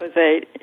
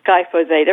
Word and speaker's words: glyphosate: glyphosate, correct my pronunciation if I have it wrong, the chemical that is sold. – Glyphosate, yes. – How do you say it? – Glyphosate. Everyone has trouble with it.